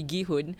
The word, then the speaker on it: Gi-hoon: I think my problem with that is that it feels as though Gi-hoon, sorry, I realize I've been pronouncing his name Ji-hoon, but it should be Gi-hoon, has been just riding on his sheer, like the sheer misfortune of others to get to where he is.